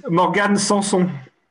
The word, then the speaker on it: Morgan Sanson: Morgan Sanson.